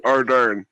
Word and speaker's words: Ardern: It's Ardern. Ardern. – No, American Ardern. Yeah, Ardern.